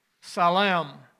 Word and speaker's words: salam: In the Muslim world, it is pronounced more like Salem, salam.